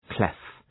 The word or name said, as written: clef